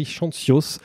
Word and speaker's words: Chantzios: Alors vous avez le droit de reprendre la prononciation de votre nom de famille. – Non, non, c'était très bien. Chantios.